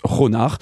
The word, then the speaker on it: Khonnar: Alors ça s'écrit presque comme connard, mais ça se prononce Ronard.